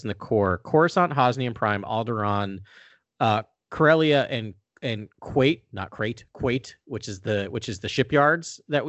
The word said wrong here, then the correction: Kuat: Yeah, I always pronounced it Kuat as well. – Let's go with Kuat because that's very far away from Crate. I'm all on board with that. So Kuat, right?